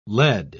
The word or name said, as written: led